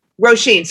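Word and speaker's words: Róisín: Roseanne Murphy? – Roisin.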